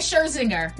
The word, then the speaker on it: Scherzinger: I say Scherzinger. – That's it. – Scherzinger? Okay. – Scherzinger, that's perfect.